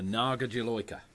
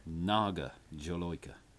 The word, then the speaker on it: Naga Jolokia: It's the Naga Jolokia.